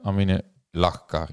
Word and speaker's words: Amine Lachkar: En toen vroeg ik het vooraf eventjes van hoe spreek je je naam nou uit? Amine Lachkar inderdaad.